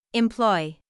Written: employ

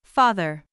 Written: father

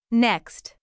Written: next

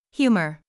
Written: humor

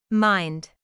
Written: mind

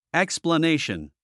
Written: explanation

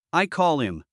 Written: I call him

